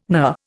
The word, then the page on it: knur: Indian